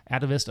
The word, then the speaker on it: Atavist: I'm not sure how to pronounce it. Atavist. Atavist, apparently.